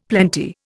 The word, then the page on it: plenty: Indian